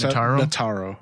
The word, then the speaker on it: Notaro: Did I say her name? – Yes, Tig, that's Notaro.